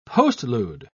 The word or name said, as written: postlude